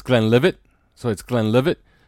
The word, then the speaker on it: Glenlivet: It's not Leavitt. Okay. So it's Glenlivet. So, okay, because I've heard some people call it leave it. I've heard some people say leave it. So, okay. Well, I've been pronouncing it Glenlivet forever.